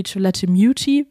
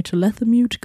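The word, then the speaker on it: Chalamet: Tony Chalathimute wird da ausgesprochen. Ich habe immer Tony Chalathimute gesagt. Das ist anscheinend nicht richtig.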